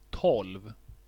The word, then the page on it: tolv: /tɔlv/